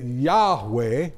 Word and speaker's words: Yahweh: Most people say today that Yahweh or Yahweh is probably the best way to describe and pronounce those four letters that are used throughout the Old Testament for God's personal name.